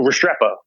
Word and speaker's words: Restrepo: Respart. – Oh, Restrepo.